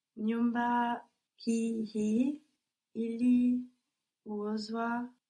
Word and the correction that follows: iliuzwa: juzi. – Nyumba hii iliuzwa juzi.